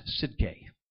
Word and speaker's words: Tsidkenu: The word we're going to look here is spelled T-S-I-D-K-E-N-U. But it's actually pronounced Sidke.